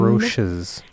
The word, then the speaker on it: Roches: Yeah, well, and here's how it's spelled: Roches. And it's their last name. – Roches. – Yeah, it's their last name. The Roches. – Like Ferrero Rocher.